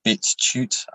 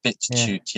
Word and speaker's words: BitChute: On a bitch. – Have you watched it? – BitChute. I keep saying it wrong. I've seen a bit of it. BitChute.